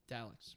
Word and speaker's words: Daleks: Blood of the Daleks? Am I saying that right? – Daleks.